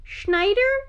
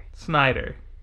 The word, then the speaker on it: Snyder: Schneider? Snyder. – Snyder.